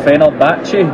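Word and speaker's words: Fenerbahçe: I believe, who ended up going to, and again, tricky pronunciation here for me, Fenerbahce. – Yeah. – Just to double check I got that correct there, John, thank you.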